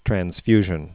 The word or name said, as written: transfusion